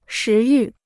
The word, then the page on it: 食欲: shí yù